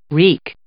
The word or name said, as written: wreak